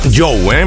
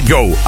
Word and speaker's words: Djo: Que si lo quieres localizar, que recuerdes que se escribe DJO, aunque él mismo ha dicho que le podemos llamar Joe, ¿eh? Perfectamente Joe. Ahí estábamos con el final del principio, End of the Beginning.